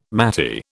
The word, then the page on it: maty: British English